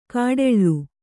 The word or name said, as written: ಘೋಟಾಳಿ